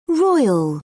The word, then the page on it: roil: British English